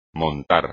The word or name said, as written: montar